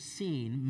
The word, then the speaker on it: Sin: It looks like it's sin, but it's Sin. It's pronounced Sin.